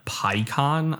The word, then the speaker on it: Peikon: So, this week it is Evan Picon, I believe is how you pronounce his name. P-E-I-K-O-N. You can find his stuff. His Instagram username is Evan_Picon, P-E-I-K-O-N.